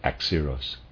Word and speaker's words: Axieros: By such souls alone could the clouds of smoke that ascended from Axiros, etc., from the Kabiri be deciphered. I'm not sure how to pronounce that. Readers aside, Axiros is spelled A-X-I-E-R-O-S, and I'm pronouncing that Axiros.